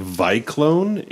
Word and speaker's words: Vyclone: It's called Vyclone. It's Cyclone spelled with a V, V-Y-C-L-O-N-E.